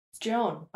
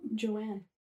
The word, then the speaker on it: Joan: And is it Joan or Joanne? – Joan. I said called Joan. – Oh my goodness, I've been mispronouncing him. And that is really embarrassing. – Well, now I feel bad because what if I'm saying it wrong? – No, now that I've had 5 seconds to think about it, that is how you pronounce Joan. – J-O-A-N.